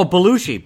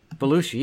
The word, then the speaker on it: Belushi: Oh, Belushi. Belushi Toilet. – It's Belushi? – Belushi, yeah, like the great actor, John Belushi.